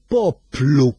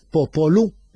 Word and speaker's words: poplu: L-aċċenti jiddeterminaw ħafna, pereżempju kultant anka idajfu sillaba u jwaqgħuha per eżempju, kienem kelma li il-Maltin ta' qabilna kellhom żonna, ħaduha mit-Taljan, popolo, u x'għamlu? Poplu. Mhux popolu. Era, għax l-aċċent tefa' fuq l-ewsa. Poplu.